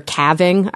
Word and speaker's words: calving: So the idea here is, if there's a calving event or calving, I still don't know how to pronounce that. There's calving. An L in it. I'm going to say calving. Is it calving? – Yeah.